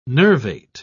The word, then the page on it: nervate: British English